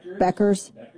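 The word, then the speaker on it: Bueckers: In a video that Reese posted, she was talking with Dallas Wings guard Paige Buck, how do you say her name? – Beckers.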